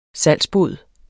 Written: salgsbod

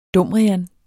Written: dumrian

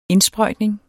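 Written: INDSPRØJTNING

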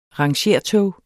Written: rangertog

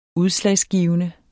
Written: udslagsgivende

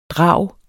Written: drag

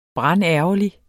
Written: brandærgerlig

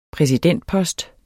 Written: præsidentpost

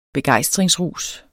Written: begejstringsrus

